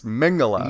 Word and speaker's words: Mengele: Mengele. It's Mengele.